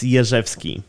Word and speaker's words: Jerzewski: What about the correct Polish pronunciation? – It's Jerzewski.